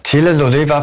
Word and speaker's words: Telenovela: Nee, wie heißt das nochmal? Ich kann es nicht aussprechen. Telefonela? Telenovela für Arme.